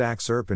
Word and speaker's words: AXRP: This podcast is called AXRP, pronounced axe-urp and short for the AI X-Risk Research Podcast.